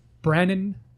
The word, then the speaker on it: Branan: Brannan Walker.